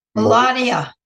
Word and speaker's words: Melania: Melania.